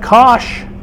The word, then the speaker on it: cosh: And it turns out that cosh, that's how you pronounce it, cosh squared minus sinh squared is equal to 1.